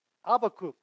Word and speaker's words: Habakkuk: They pronounce this word Habakkuk. We thought that was a better pronunciation than the English one.